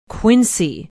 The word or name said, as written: quincy